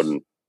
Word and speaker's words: Bowden: And is it pronounced Bowden or Bowden? Regardless. – It's Bowden. – Okay, it's Bowden.